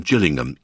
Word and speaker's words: Gillingham: Sir Keir Starmer launched Labour's election campaign in Gillingham, east of London, in the Thames estuary. He pronounced the name correctly, the first G is soft, and cracked a lame joke about the local football team.